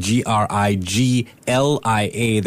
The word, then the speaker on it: Griglia: It's spelled G R I G L I A, that G in the middle is silent.